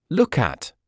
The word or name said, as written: look at